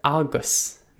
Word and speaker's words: agus: For example, the word agus means and, and it's spelled A-G-U-S. Again, I'll say it. Agus.